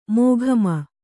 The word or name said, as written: ಮೊಖಾಮು